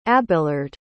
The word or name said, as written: abelard